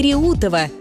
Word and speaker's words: Реутов: В новостях и разговорной речи часто возникает путаница с ударением в названии подмосковного города Реутов и станции Реутова. И ошибка здесь встречается регулярно. Правильно, город Реутов с ударением на «е» и железнодорожная станция Реутова с ударением на «у».